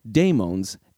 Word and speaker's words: daimon: In order to distinguish demons from daemons, in modern discussions, the latter is generally transliterated into English as D-A-I-M-O-N, and it often maintains a unique pronunciation.